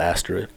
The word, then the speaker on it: asterisk: Not that that's really an excuse, but pronunciation is asterisk. For the record, I know how to say it.